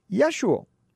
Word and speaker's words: Yeshua: French, Jesu, pronounced Yesu, basically, is the Greek form of Aramaic Yeshua. Yeshua, basically Y, E, S as in Sam, H, U, A as in apple.